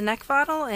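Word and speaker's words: Neckvatal: You'll be able to find me on LinkedIn just by my name, and that's Brenda Neckvottle, and the last name is spelled N E C K, like the thing you want to choke.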